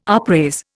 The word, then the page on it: upraise: Indian